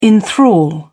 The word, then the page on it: enthral: British English